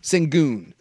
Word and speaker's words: Şengün: Şengün.